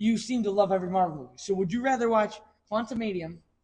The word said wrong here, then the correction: Quantumania: If you say that name wrong one more time. – Quantumanium? – Quantumania. – Quantumania, sorry. – There's no M. It's not a UM. It's an A.